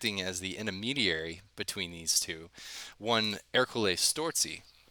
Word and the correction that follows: Strozzi: That's my BS Italian pronunciations. Strozzi. So,